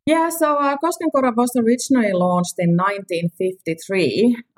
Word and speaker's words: Koskenkorva: And if we now sort of hone in on Koskenkorva, did I say that kind of okay? Koskenkorva. – Yes, Koskenkorva. I know it's not easy to pronounce, but yeah, that's the real name of the place.